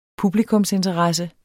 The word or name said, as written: PUBLIKUMSINTERESSE